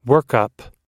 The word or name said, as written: workup